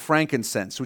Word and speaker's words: frankincense: Then it says they dropped off frankincense, which is better pronounced frank incense. It's just a type of incense.